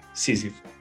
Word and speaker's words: Sísifo: Sísifo.